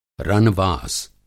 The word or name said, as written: रनवास